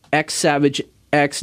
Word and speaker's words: X Savage X: And so if you're looking for this band, they are the way you can spell it is X Savage X, but the X's are silent, and you can just check them out. X Savage X.